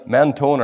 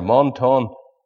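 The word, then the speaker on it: Menton: And he found that little place down there in the south east corner of France, near to the border with Italy, Mentone or Montone, as some people pronounce it.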